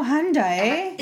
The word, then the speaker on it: Hyundai: Tricky. Silent I. – Hyundai. – No, Hyundai. – Hyundai. – There you go. – Like Sunday.